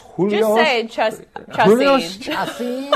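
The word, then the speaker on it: Chacín: Julio Chacin on the mound.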